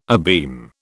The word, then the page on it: abeam: British English